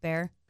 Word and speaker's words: Bexar: And in San Antonio, you know, it's in Bexar County, but it's spelled B-E-X-A-R. – Right. – Bexar, but pronounced Bear.